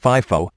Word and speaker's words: FIFO: Pronounced FIFO, the company assumes that the first item making its way into inventory is the first sold.